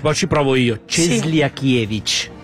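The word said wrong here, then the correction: Cieślakiewicz: È giusto? Ho detto bene. – Cieslakiewicz. – Eccolo là, Ciesla è il primo.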